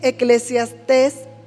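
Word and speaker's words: Eclesiastés: Dice Eclesiastes, hoy ahora sí lo dije bien, 3.11.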